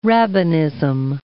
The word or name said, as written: rabbinism